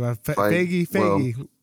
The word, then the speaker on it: Feige: The producer, me and Manny didn't look up the name, but Kevin Feige, I guess that's how you say it. – Feige, I believe. Feige.